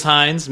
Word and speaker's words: Hines: My last name is Hines, Matthew Hines. Someone might, yeah, like the ketchup. It's spelled a little differently.